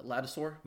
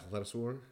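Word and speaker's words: Ladouceur: We had to look up the way to pronounce his name, but LP Lattisor. – Lattisor.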